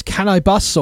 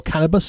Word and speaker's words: Canibus: Before that, it was Can I Bus or Cannabis, so he wants to pronounce his name. C-A-N-I-B-U-S.